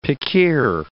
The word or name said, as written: pickeer